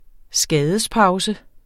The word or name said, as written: skadespause